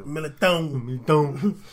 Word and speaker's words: Militão: Carvajal, Ramos, Varane, Nacho, Marcelo, Odriozola, Mendy, and Militao. Their midfield consists... – You gotta say it like the way you're supposed to. – Militão.